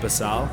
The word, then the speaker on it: Basal: Yeah, so I'm running an Instagram called The Life of Basal, which is spelt normally. – Well, see, I'm sure in Canada, I would say basal. – Yeah, you can pronounce it however you want. I'm not fussy. – So it's B-A-S-A-L. – Yes, that is... – Or basal. Because here, there's a margarine called basal. – Oh, really? – B-E-C-E-L. – Oh. Okay, so it's pronounced closely to the way you pronounce that.